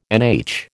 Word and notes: NH: American English

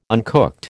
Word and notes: uncooked: American English

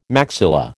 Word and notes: maxilla: American English

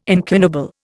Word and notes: incunable: Indian

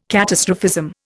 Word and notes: catastrophism: Indian

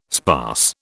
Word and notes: sparse: British English

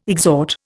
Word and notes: exhort: Indian

